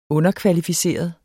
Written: underkvalificeret

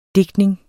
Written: DIGTNINGEN

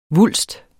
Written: vulst

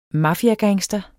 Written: MAFIAER

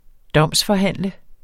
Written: domsforhandle

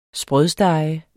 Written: sprødstege

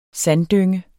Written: sanddynge

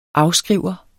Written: afskriver

